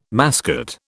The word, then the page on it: mascot: British English